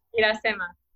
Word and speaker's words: Irasema: But so for the listeners, if you want to get to know Irasema, am I saying that right? I always am nervous. – Irasema. – Okay. Irasema.